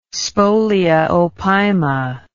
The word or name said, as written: spolia opima